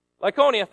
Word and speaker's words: Lycaonia: Oh, sorry. How do I say that word? Lycaonia? Lycaonia, thank you.